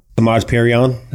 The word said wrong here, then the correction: Samaje Perine: I took Samaje Perine.